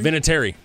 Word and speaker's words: Vinatieri: Yeah, and how do you even pronounce it? Vinatieri? – Vinatieri.